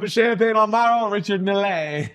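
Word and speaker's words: Richard Mille: It's Richard Milley, right? – Yeah, Milley.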